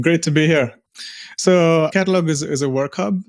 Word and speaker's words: Qatalog: He is the founder of a company called Qatalog, Qatalog with a Q.